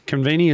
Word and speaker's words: Convenia: So, Convenia.